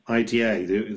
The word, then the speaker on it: Ida: Do you pronounce the film Ida, IDA, the Polish film from 2013? – Yes, that's absolutely right. Ida, or in Polish it's pronounced actually Ida.